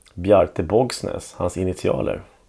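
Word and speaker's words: Bogsnes: Borgsnes. Hur uttalas? – Borgsnes. – Ja. Borgsnes. Ja.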